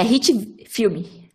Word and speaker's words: HitFilm: Rich Videos? – É, HitFilm. Eu falei errado, Hit, eu acho. HitFilm. É, HitFilm. – Hit de pancada.